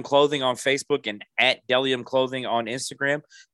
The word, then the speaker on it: Bdelliom: That's B-D-E-L-L-I-O-M, pronounced Delium. Those of you that got my shirts know that it is an odd spelling, but you see what I'm talking about. Again, it's Delium, B-D-E-L-L-I-O-M.